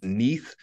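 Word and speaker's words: níð: And the last one was this concept roughly pronounced Neith, which was N-I-O with a squiggly Norse line over it that I don't know what it's called.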